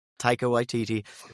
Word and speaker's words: Taika Waititi: Taika Waititi. – Taika Waititi. – Say it for me, please. – Taika Waititi? – Taika Waititi.